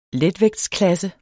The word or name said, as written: letvægtsklasse